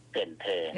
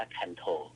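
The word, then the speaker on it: Cần Thơ: เกินเธอถ้าอ่านตามภาษาอังกฤษจะอ่านว่าคันโถ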